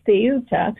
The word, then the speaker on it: Ceuta: And the visible video of the swarms of people coming through the water onto the shore of a town called Ceuta, is pronounced in Spanish with a T-H, Ceuta.